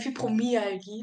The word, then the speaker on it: Fibromyalgie: Habe ich das jetzt richtig ausgesprochen? – Fibromyalgie mit Korrekt.